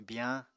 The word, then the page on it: biyañ: Apatani